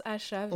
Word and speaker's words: ASAV: Ça s'écrit A-S-A-V. – Oui on, le prononce à Chave.